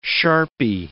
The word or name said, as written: sharpy